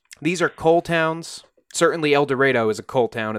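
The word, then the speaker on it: Eldorado: The heart of Egypt is the towns of El Dorado, that's spelled like El Dorado, but I am assured that it is pronounced El Dorado.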